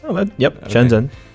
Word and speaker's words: Shenzhen: Shenzhen. – Shenzhen.